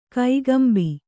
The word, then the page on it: ಕೈಗಂಬಿ: kaigambi; Kannada